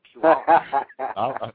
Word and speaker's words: Puyallup: And I can even pronounce it. Puyallup.